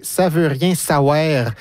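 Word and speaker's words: Saouère: Je dis bien « savoir », c'est parce que c'est éplé S-A-O-U-E, accent grave, R-E. Mm-hmm. Donc, c'est éplé de manière phonétique, comme on le prononce.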